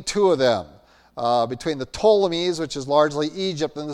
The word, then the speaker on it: Ptolemies: And so the Ptolemies, it starts with the P, P-T, but the P is silent, were Egypt.